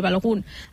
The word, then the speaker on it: Balogun: El tercer, ara l'esmentava suposo que es pronuncia així, el nord-americà Balogun. – Sí, jo li dic així, Balogun.